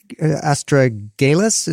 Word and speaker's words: astragalus: I got orange and acerola cherry, astragalus is how you pronounce it.